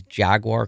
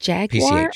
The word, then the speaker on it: Jaguar: Is it Jaguar PCH. Or Jaguar? – Oh, that's a good question. I just said Jaguar. – I think it's Jaguar. – Jaguar? I have to say it like that? – Yeah.